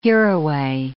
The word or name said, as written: hereaway